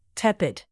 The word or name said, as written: tepid